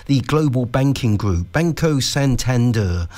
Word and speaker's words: Santander: is Santander, as generally pronounced in the UK, or Santander, generally pronounced in Spanish.